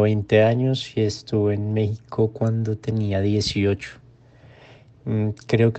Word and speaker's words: México: ¿Alguna vez te has preguntado por qué México se escribe con X pero se pronuncia como si fuera una J?